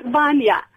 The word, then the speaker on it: Vanya: Valia is in the Hanworth. – Oh, you're wrongly pronounced my name. I'm sorry, darling. It's Vanya. V-A-N-Y-A.